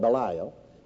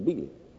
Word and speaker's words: Belial: We call it Belial. Actually, the correct pronunciation is Belial.